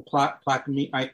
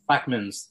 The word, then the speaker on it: Plaquemines: The Plaquemines. – Plaquemines, thank you. – Plaquemines.